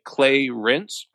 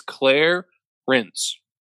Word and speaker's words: Clarence: Just for note, I don't think you pronounce it like Clay Rinse. I think it's Claire Rinse. – Claire, and then like rinsing as in like rinsing like a dishcloth.